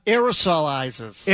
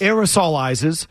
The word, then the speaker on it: aerosolizes: Singing, to a greater degree than talking, aerial sizes, how would I pronounce that word? – Aerosolizes. – Aerosolizes.